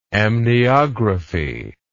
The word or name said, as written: amniography